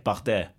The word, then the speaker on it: Bardet: Bardet.